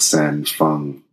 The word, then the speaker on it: San Feng: You did really good pronouncing the name earlier, San Feng.